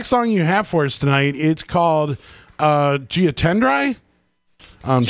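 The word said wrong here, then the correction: J'attendrai: How do you pronounce it? – Yeah, it's a French song. It's called Je Tendrai.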